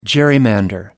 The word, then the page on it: gerrymander: British English